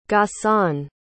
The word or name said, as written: ghassan